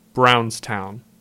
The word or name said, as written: Brownstown